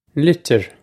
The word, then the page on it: litir: Irish